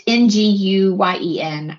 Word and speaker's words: Nguyen: I always mispronounce this last name wrong. It's like Nguyen. It's Nguyen. I apologize.